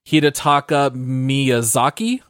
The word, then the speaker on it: Hidetaka Miyazaki: Now, I did see that the guy in charge of the game Hidetaka Miyazaki, I think is how you pronounce Nailed it.